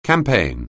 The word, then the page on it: campaign: anglais